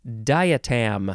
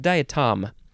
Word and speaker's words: diatom: The next word, bum bum bum bum bum bow, diatom. I think it's diatom and not di. Oh no, it might be. No, it's diatom. Diatom, because it is spelled D-I-A-T-O-M, diatom.